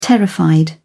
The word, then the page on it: terrified: British English